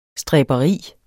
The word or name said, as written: stræberi